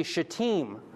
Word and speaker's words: Shittim: You got to be careful how you pronounce this one. This is actually Shittim. The Im, it's an Im ending in Hebrew.